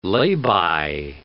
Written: lay-by

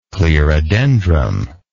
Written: clerodendrum

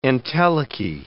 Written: entelechy

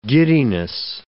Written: giddiness

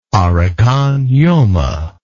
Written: Arakan Yoma

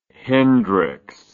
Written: hendrix

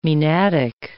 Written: maenadic

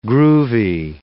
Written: groovy

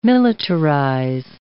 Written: militarize

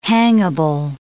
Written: hangable

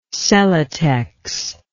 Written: celotex

